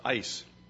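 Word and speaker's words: eis: The key word is for, F-O-R. Actually, in the Greek language, it's E-I-S, which is pronounced ice.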